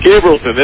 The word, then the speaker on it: Gabrielson: L-S-O-N. – Gabrielson. That's how I would pronounce it. – I completely agree. Gabrielson. – It's not Gabrielson. It's Gabrielson. – Gabrielson. But the E is sort of silent then. – Exactly. – Gabrielson. – Gabrielson. – Gabrielson.